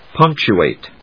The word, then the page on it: punctuate: pˈʌŋ(k)tʃuèɪt; punc・tu・ate